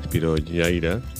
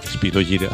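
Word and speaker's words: Spyro Gyra: Spiro Gyra, lo he dicho mal, Spiro Gyra. Después de recomendártelo, decirlo pues en latín, ya ves.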